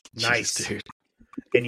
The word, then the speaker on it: gneiss: If you Google it, G-N-E-I-S-S, that's a rock too. Night. It's pronounced nice.